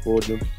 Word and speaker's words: Fulghum: So it's Darius Fulghum.